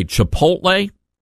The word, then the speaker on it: Chipotle: By the way, Chipotle.